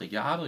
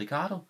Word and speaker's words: Ricciardo: Jeg troede, det udtales Ricciardo. Jeg har sagt Ricciardo hele tiden. Og tænkte, de der danske kommentatorer, de siger kun Ricardo, fordi de ikke kan finde ud af det andet. Nej, han vil gerne have, at det blev udtalt Ricardo.